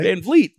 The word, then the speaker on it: Van Vliet: Like people are like Chris Van Vliet. Yeah, I get that all the time. – I didn't think about that. – Thank you for pronouncing it correctly. – Van Vliet.